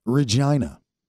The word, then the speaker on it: Regina: The capital city is Regina. – Is what? – Regina. You know, I just want to get back up on that pogo stick. Regina. – Mm-hmm. Regina. – It rhymes with vagina.